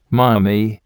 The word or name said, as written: mummy